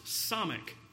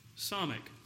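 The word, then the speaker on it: Samekh: By the way, this one is pronounced Samech. Samech.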